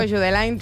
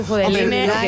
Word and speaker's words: Judeline: Yo le digo Jude Lyne, tú Judeline.